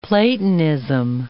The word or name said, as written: Platonism